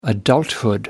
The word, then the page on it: adulthood: American English